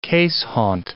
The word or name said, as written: keeshond